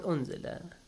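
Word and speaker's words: أُنزِلَ: Oh, law la, the mad letter is in the word la, and unzila, the Hamza is in the word unzila. The reason for mad, the Hamza. It's in another word, that's why. Unzila. How do you say it? – Unzila.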